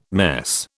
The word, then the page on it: mess: British English